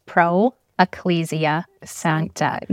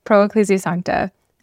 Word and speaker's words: Pro Ecclesia Sancta: You said it really fast, but it's Pro Ecclesia Sancta.